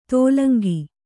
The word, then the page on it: ತೋಲಂಗಿ: tōlaŋgi